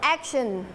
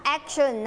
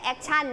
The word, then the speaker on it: action: action ออกเสียงว่า action นะคะไม่ใช่ action